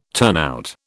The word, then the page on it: turnout: British English